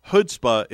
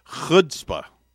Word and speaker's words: chutzpah: And we have to make sure that we say it properly. It's not chutzpah. It's chutzpah.